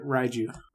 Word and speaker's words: Raiju: Second ability, Raiju. Am I saying that right? – Yep. – Raiju? – Yep, Raiju.